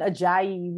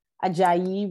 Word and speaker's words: Ajayi: Even Ajayi, which is my last name, Ajayi, which is spelled just like it's, you know, it looks just like it's spelled, and people will just make it ugly. I'm like, what? That's the easiest last name in the face of the earth. Ajayi, like right there. It's right there.